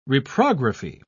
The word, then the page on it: reprography: British English